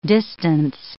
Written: distance

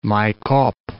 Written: Maykop

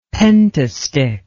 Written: pentastich

